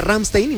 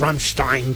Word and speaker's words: Rammstein: Pero esta es principal una de las cosas que cambiaron en el plan original de este rollo de Rammstein, ¿no? Que de hecho no habíamos dicho la banda, ¿no? Rammstein. Una vez me regañaron porque yo decía Rammstein y me dijeron, no, es Rammstein.